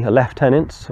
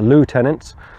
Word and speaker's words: lieutenant: started off as a lieutenant, or as the Americans pronounce it, a lieutenant.